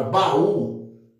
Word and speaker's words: baú: Então, por exemplo, na palavra baú, eu vou dividir aqui ba-u. Então, o U forma um hiato com o A.